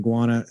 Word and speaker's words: Guyana: People's Temple Commune in Guyana.